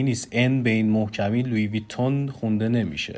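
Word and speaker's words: Louis Vuitton: ببینید در زبان انگلیسی هم سعی شده که ریشه های تلفظی این کلمه به فرانسه حفظ بشه، جوری که در زبان انگلیسی هم حتی شما این کلمه رو لوی ویتون میشنویدش. – لوی ویتون، بله.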